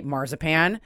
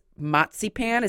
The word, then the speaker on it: Marzipan: I love, as of course, being in American English speaker, I will always say marzipan. But the fact that it's matzipan, is that, am I saying it right? Matzipan? Is that yeah, matzipan is, matzipan is matzipan is German.